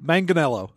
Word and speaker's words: Manganiello: What's his real pronunciation? – Manganiello.